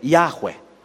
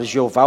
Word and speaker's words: Yahweh: Eu costumo dizer Yahweh. Porque o meu professor de hebraico, o professor, saudoso professor Carlos Oswaldo Cardoso Pinto, ele pronunciava assim. Então aprendi com ele, então pronuncio assim também. Em vez de falar Jeová ou Javé, eu geralmente pronuncio Yahweh. Yahweh. É Y-H-W-H. Veja como pronunciar isso. Y-H-W-H.